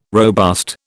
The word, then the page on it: robust: British English